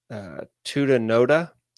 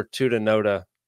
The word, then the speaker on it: Tutanota: The best three that I know of are Librem Tutanota, I think is how you pronounce it. T-U-T-A-N-O-T-A.